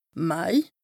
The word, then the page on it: Mai: Bas-Rhin; Strasbourg